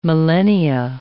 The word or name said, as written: millennia